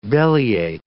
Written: bellyache

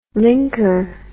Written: linker

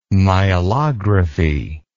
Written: myelography

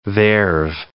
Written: there've

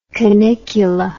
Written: canicula

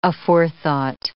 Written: aforethought

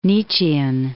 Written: Nietzschean

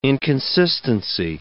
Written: inconsistency

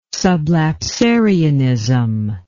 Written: sublapsarianism

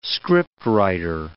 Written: scriptwriter

